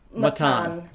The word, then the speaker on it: Mattan: Your novel, The Fortune Men, follows the case of Mahmoud, is it Matin or Matin? How would you say that? – Matin.